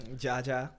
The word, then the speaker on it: Jaja: Jaja, J-A-J-A. Rap name, Jaja. It's two zeros at the end, but you don't pronounce the zeros.